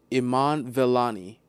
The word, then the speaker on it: Iman Vellani: I have been saying her name wrong this entire time, and I feel so bad. Iman Vellani, Iman Villani, Iman you got my heart and soul.